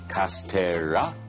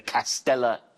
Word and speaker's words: castella: 그리고 발음 기억하세요. Castella. 아니야, castella. 또 아니고 castella. 네, 라라라라라. 노래처럼 해주세요.